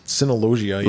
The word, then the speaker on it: Cineloggia: Oh, and we should also say that the Mark Patton appearance was at Cinelogia. Is that how you say it? – Cinelogia. Yeah. – Logia.